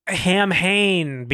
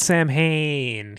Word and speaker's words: Samhain: It's all about Hamhain, because he didn't say Samhain in this one. He's pronounced it differently. – Oh, yeah. Yeah, the festival of whatever. – Who cares about the explanation? It's Samhain.